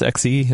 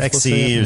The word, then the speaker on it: Xe: But people are disappointed, and they're not sure that Intel can do it, I think, honestly, is the feeling now from Z. But obviously that is premature. Right? – Wasn't it pronounced XE? – It is. – XE or Z, but I think definitely it is premature to judge everything based on these development parts.